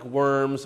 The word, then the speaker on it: Worms: And boys and girls, in case you're wondering, yes, it's spelled like worms, but it's not worms, it's a German city, Worms.